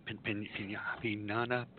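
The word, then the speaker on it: piñata: The Pianda. Pinata. How do you say that word? – Okay. The word you're trying to say is pinata. Say it from pin.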